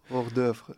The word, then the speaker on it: hors d'oeuvre: je begint met de, uh, moet ik het goed uitspreken, hors de varié. – Hors d'oeuvre. – Hors ja.